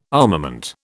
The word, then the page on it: armament: British English